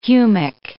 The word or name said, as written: humic